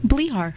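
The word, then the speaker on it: Blichar: Blihar. – Blihar. – Yeah, oh, it's a silent C-H.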